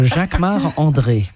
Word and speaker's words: Jacquemart-André: Oh, it's because I'm French. So Jacques Marc André.